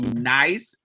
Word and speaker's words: Nice: And he went to Nice, France. And so it was spelled like nice. So I would always call it nice. It's spelled like nice, but it's pronounced Nice.